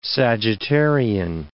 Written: Sagittarian

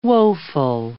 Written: woeful

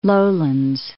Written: Lowlands

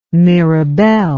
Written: mirabelle

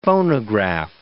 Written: phonograph

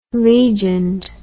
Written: legioned

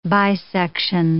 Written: bisection

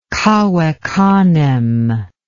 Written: cave canem

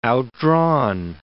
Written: outdrawn

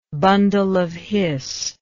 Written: bundle of his

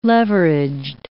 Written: leveraged

